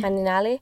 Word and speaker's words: Malinali: Malinali? Yes, that's how you say it, right? – Malinali.